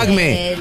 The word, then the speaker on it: acmé: Es marca acmé. Es una marca. – Trucho. – Camino. – Pero con acento en la E, acmé.